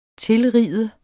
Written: tilride

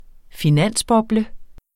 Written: finansboble

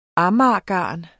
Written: AMAGERGARN

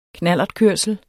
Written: knallertkørsel

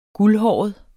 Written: guldhåret

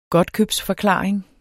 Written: godtkøbsforklaring

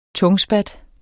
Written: tungspat

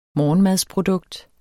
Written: morgenmadsprodukt

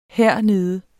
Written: hernede

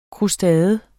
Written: krustade

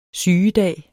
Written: sygedag

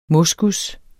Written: moskus

